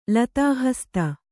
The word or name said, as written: ಲತಾಹಸ್ತ